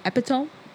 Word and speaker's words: epitome: You know, my mom is the, how do you pronounce it? Epitome? – Epitome. – Listen, that's where the accents come into place. In my head is epitome. But, you know, as Dami said, American is epitome.